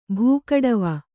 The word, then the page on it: ಬಿಗಡಾವು: Kannada